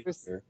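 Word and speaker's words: Ødegaard: It's Martin my Udegger. – Seriously, that's actually how it's pronounced.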